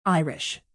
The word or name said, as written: irish